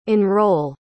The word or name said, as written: enrolle